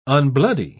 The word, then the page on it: unbloody: British English